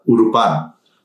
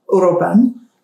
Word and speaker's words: Uruapan: It's a Urupan. – Urupan. – U-R-U-A-P-A-N.